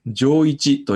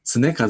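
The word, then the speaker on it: 常一: さて、最後になりますが、西岡棟梁のお名前を上一と読まれていましたが、正しくは常和と読みます。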